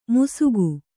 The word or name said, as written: ಮುಸುಗು